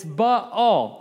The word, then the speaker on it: Baal: AA is not a diphthong, it's two separate. Yes? All right. It's two separate syllables. It's Baal.